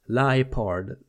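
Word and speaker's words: Liepard: Lipard ska det nog uttalas. För att det är en leopard som ljuger står det här.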